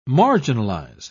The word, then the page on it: marginalise: British English